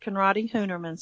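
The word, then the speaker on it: Conradi-Hünermann: It is actually pronounced Conradi-Hunermann syndrome, and it's a tough one, and the doctors don't know a whole lot about it, and we have run into that.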